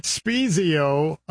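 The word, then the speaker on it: Spiezio: Next up, we've got a movie, and I think I'm saying this right, Spezio, a faith-based documentary exploring the life of Major League Baseball player Scott Spezio. – You're saying it exactly right.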